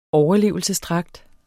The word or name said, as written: overlevelsesdragt